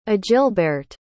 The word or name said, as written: agilberht